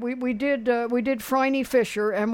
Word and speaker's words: Phryne: Yeah, Phryne. It rhymes with briny.